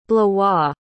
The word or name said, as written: blois